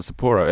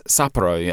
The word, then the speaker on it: Sapporo: It's not Sapporo, it's Sapporo. Everyone's, I even learned how to say it properly.